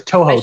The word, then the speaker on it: Toho: Is it Togo? How do you say the Japanese company that makes Godzilla? – Oh, I have no idea. I'm sorry. – Toho? I think. Sorry, it's Toho. Toho.